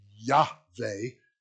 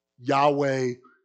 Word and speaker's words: Yahweh: And I'll put it in English, but Yod, Hey, Vav, Hey, Yahweh, is how we would pronounce it as best we know in English. That's Yahweh.